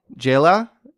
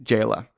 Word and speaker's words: Zsela: Her name is pronounced Jayla, but I had no idea how that it was pronounced that until I looked, like, found her saying it, because it's spelled Z-S-E-L-A. – Z-S-E-L-A. – Yes. – Okay. – But it's pronounced Jayla.